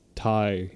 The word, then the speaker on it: Thai: It's pronounced Thai. – Thai. – Yeah, because you know, Thigh Place.